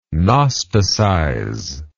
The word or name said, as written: gnosticize